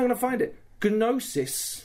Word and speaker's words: Gnosis: It's Gnosis. – Gnosis Studios. – We've been getting it wrong the entire time. – Hey. – The G is silent.